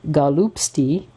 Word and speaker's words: golubtsy: I have been promising you my mother-in-law's recipe for stuffed cabbage, or galupsy, I'm going to spell it, G-O-L-U-B-T-S-Y, which was her mother's recipe for stuffed cabbage for ages.